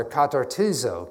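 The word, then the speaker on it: katartizo (καταρτίζω): I like to dig and find these words because the word restore, katarizo, and I pronounced that wrong, sorry, katartizo.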